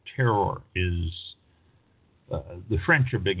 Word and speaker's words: terroir: T-E-R-R-O-I-R, terror, it's pronounced.